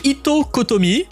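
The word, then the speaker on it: Ittoqqortoormiit: Itokotomi, on n'a pas l'impression que ça se prononce comme ça vu l'écriture, mais bon, certes, ça veut dire grande maison en groenlandais.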